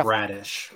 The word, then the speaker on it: Bradish: Oh, Kyle Bradish. – Yeah, that. – That's how you pronounce it.